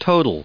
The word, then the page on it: total: verb